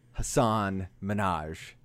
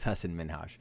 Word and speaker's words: Hasan Minhaj: And that is former correspondent of The Daily Show, Hasan Minhaj, or how some people pronounce it, Hasan Minhaj, but it's Hasan Minhaj.